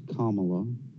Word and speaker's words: Kamala: It's pronounced Kamala.